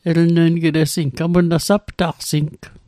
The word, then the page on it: saptaqsing: Mi'gmaq